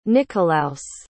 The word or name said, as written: nicholaus